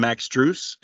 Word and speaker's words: Strus: And then um, later would agree to a deal, uh, a trade to bring in Max Strus. Am I pronouncing that correctly? – That is correct. Yes.